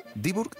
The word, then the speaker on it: Dieburg: Eh, ¿Cómo ha sido tu vida para terminar en Diburg ¿Lo digo bien? Diburg, Alemania? Sí, lo dices muy bien, pues yo nací en Tetuán, en una ciudad preciosa al norte de Marruecos.